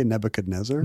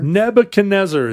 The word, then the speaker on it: Nebuchadnezzar: Now, how do you pronounce that? – I say Nebuchadnezzar. – Nebuchadnezzar is how they say it.